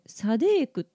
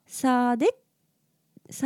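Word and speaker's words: Sa Đéc: くないわけではないんですが「タインフォーサデック」って書いてるのであの「サデック」っていうのが日本語にするとですね「サデーク」っていうふうに結構言われるみたいです。でも多分発音的には「サーデッサーデッ」みたいな感じなので、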